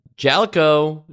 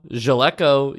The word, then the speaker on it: Jaleco: Uh, Jalico, Jaleco, you choose.